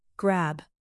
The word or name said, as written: grab